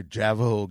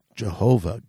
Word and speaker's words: Jehovah: So, like, if I say, hey, how's your Javajo God? You can say, excuse me, it is Jehovah God. – Stop saying Javajo, you demon.